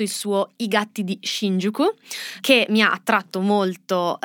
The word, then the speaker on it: Shinjuku: E Shinjuku è un quartiere di Tokyo, sto cercando di pronunciarlo da giapponese, tra l'altro apprezzalo. – Mi sembra che ti sia venuto molto bene.